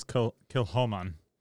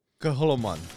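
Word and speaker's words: Kilchoman: This is, I actually looked up the pronunciation. It's Kilhoman. – Kilhoman.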